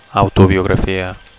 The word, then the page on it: autobiografia: Catalan